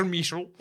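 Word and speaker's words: Michell: le film est réalisé par Roger Michel. – Alors, je pense que je prononce très mal. Je pense que c'est Roger Mitchell.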